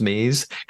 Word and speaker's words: Mayes: And it's her surname is spelled M-A-Y-E-S, but it must be pronounced Mays.